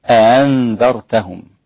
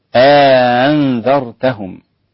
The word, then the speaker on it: أأنذرتهم: أأنذرتهم، وهناك فرق بين الاثنين، أأنذرتهم مش أأنذرتهم، لا أأنذرتهم، والهمزة والهمزة مع الإدخال يعني إدخال ألف بين الهمزتين فيصير النطق بها هكذا أأنذرتهم أأنذرتهم.